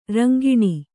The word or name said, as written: ರಿಂಗಣ